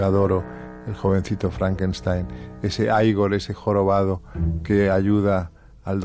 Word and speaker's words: Igor: No, se pronuncia Igor. – A mí me dijeron que era Igor.